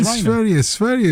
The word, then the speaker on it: Sverige: No, Sverige is the right, correct pronunciation. Well done, you, Ruby. Yeah, it's Sverige.